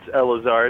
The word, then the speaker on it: Eleazar: Yeah, it's Eleazar. It's a Hebrew name. – Son of God. – I know that. I was trying to give it a Spanish pronunciation for some reason, and I just messed it all up.